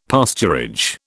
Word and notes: pasturage: British English

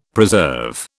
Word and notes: preserve: British English

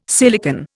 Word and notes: silicon: Indian

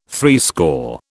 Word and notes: threescore: British English

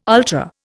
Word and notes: ultra: Indian

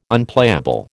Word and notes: Unplayable: American English

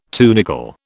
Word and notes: tunicle: British English

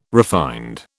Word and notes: refined: British English

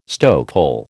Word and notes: stokehole: American English

